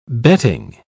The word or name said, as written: betting